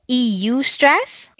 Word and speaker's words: eustress: Did you say EU stress? – EU, that's how it's actually pronounced, eustress.